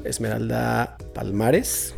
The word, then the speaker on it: Esmeralda Palmares: Estamos con Esmeralda Palmares.